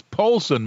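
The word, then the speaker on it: Polson: A call in Poison, Montana. Hello. – Hello, in Poulsen. – Oh, Poulsen. I did mispronounce that. It is Poulsen, Montana.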